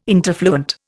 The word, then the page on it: interfluent: Indian